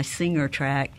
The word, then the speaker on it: Singer: Some people say it like it's an A, but it's really an I, Singer tract.